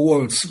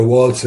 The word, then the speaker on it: Waltz: Kenneth Waltz, o Waltz, come lei dice, pronuncia, io lo faccio alla tedesca perché è di origine tedesca il nome, secondo me.